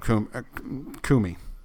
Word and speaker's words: Comey: But that's not what Coomer said. Did I say that right, Coomer? Is that how you say his name? – No, Coomey. – Coomey. – Is it Coomey? – Coomey. – C-O-M-E-Y. Coomey.